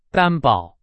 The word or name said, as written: 担保